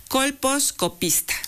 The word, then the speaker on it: colposcopista: La doctora Rosa María Morales Tepatl es médico ginecóloga colposquista. No, colposquista. Bueno, discúlpeme usted, pero bueno, hoy... estoy medio trabada. Colposcopista. – Así es. – Ya lo dije bien.